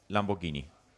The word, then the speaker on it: Lamborghini: Bilmærket hedder Lamborghini.